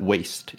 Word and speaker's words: W.A.S.T.E.: He refuses to say any more than explaining that waste is pronounced one letter at a time. W-A-S-T-E.